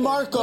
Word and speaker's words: Markle: Rhymes with sparkle. – Meghan Markle.